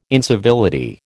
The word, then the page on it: incivility: American English